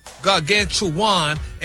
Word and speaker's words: gargantuan: Sounds like you missed a syllable there, sir. – Oh, gargantuan. – No. – No, sir. – Maybe you should just make some more videos with you with different clothes on with Stephanie Mills music like you've been doing. – Look at the breakdown of the word. – It's pronounced gargantuan. – Yeah, now you in the neighborhood.